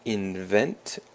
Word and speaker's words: invent: And the word invent also has the emphasis on the second syllable, which just means 发明 or 创造.